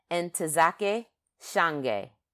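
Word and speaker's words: Ntozake Shange: And so what I did today or yesterday is got on that thing and typed in the pronunciation so I could say it right. And it's Entezake Shange.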